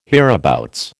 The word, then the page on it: hereabouts: American English